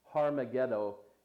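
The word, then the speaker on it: Harmagedon: Harmageddo is actually how it's pronounced, and it means the high place of Megiddo.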